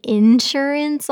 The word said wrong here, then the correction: insurance: all my life, I guess, and it's insurance. I was putting the wrong emphasis on the wrong syllable.